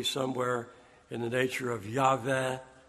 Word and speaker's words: Yahweh: because there is no W sound in spoken Hebrew. It's a V. So Yahweh would be closer to it.